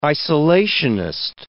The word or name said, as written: isolationist